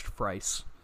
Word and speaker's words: Fries: It's spelled Fries, but it's pronounced Frice.